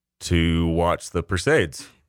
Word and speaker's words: Perseid: That's not how you say it, apparently. It's like Perseus. It comes from the root word Perseus. So it's like Perseid. – Perseids. – Yeah, Perseid meteor shower.